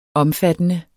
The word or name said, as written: omfattende